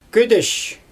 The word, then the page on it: ködesh: Xyzyl